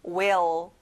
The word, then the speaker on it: will: 자, 끝에 어 발음까지 정확하게 내보세요. will.